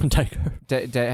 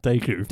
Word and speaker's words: Daegu: Does Daegu have... – Daegu. – How do you say it? – Daegu. – Daegu, sorry. Does Daegu have like landmarks?